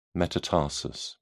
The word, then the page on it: metatarsus: British English